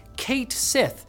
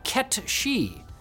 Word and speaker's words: Cait Sith: Square Enix has sided with fans who pronounced it phonetically in English as Kate Sith, disappointing others who interpreted it as Ket She, based on the name's origin in Gaelic mythology as a black cat spirit that steals the souls of the dead.